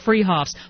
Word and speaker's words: Fruhoffs: It's spelled Fruhoffs, but pronounced Freehoffs.